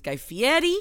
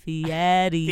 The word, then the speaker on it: Guy Fieri: Oh, I know that you're new here, but you're saying his name wrong. His name is Guy Fieri. – I know. – So you know what? – Please. – Fieri. – Fieri. Fieri. Okay.